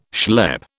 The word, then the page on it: schlep: British English